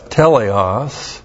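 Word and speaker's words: teleios: His special title was teleos athletes. That's T-E-L-E-I-O-S, teleos. And athletes, where we get our word athlete, A-T-H-L, long E-T, long E-S.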